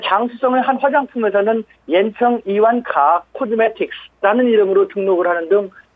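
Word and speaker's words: 이완카 (伊万卡): 중국에서는 이미 40개 회사가 발 빠르게 이방카의 중국어 발음은 이완카라는 이완카가 포함된 그 상표 등록을 마쳤습니다.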